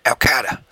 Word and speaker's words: Al Qaeda: Al Qaeda.